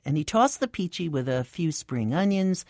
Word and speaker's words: pici: The restaurant was Fresco Italian Cafe, and the dish was a Tuscan pasta called Peachy. That's spelled P-I-C-I.